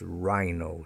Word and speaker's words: rhino: The large rhino, one of the big five animals in the African safari parks, rhino, spelt R-H-I-N-O, but pronounced R-I-N-O, rhino. And rhythm, the rhythm and blues, the music, the rhythm and blues, R-H-Y-T-H-M,